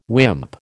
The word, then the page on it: wimp: American English